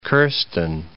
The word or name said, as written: Kirsten